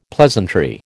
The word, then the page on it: pleasantry: American English